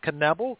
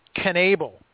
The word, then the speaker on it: Knebel: Now, Tim, I actually put down I actually put down the pronunciation because I did research on this because I thought it was Knebel because that's what I heard him when he was in college. It's actually Knebel.